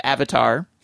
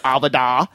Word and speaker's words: Avatar: The audiobook is narrated by Stephen Lang, one of the stars of Avatar, or as Mr. Schwarzenegger pronounces, Avada, featuring the author himself reading select passages.